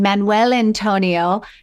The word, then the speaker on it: Manuel Antonio: We were in Manuel Antonio.